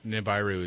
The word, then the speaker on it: Nibiru: Nibiru is the explicit pronunciation.